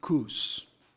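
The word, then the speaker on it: Cos: After we had torn ourselves away from the Ephesian elders, we set sail and made straight run to Cus. Some people pronounce it Cus.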